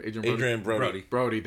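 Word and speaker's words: Adrien Brody: Adrian Brody.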